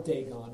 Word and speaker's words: Dagon: In toppling the idol of Dagon, and so that's how I pronounce it. Michael took a little more of the biblical languages than I did, so he may be right, but we'll call it Dagon.